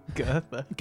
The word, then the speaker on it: Goethe: Goethe. – Goethe. Oh, he's Goethe. He's a girthy boy.